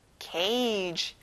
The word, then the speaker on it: cage: 새장, 우리, cage. 여기는 soft G 발음이 나서 그 소리가 안 나요.